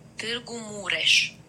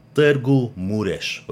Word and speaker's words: Târgu Mureș: And this time, I want to thank someone from Targum Murish. I don't know. I need the HVP bullpen on the correct pronunciation of this one listener from Târgu Mureș. – Târgu Mureș, Romania,